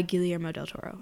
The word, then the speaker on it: Guillermo del Toro: But it on Netflix, it specifically says by Guillermo del Toro, yeah.